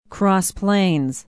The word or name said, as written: Cross Plains